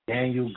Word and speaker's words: Danyeil: Y'all can find me Daniel Green. My name is spelled D-A-N-Y-E-I-L.